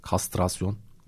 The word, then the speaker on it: kastrasyon: Katrasyon mevzusu. Katrasyon değildi o. Kastrasyon. Bir tekrardan bakmam lazım. Oldukça fazla karıştırdım cümlelerden bir tanesi, kelimelerden bir tanesi. Kastrasyon aslında doğrusu. Ben katrasyon olarak sürekli telaffuz ediyorum bunu.